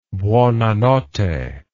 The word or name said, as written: buonanotte